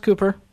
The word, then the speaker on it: Kuper: Is it pronounced Kuper? – Yep.